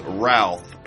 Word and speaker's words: Routh: Brandon James Routh, pronounced like South.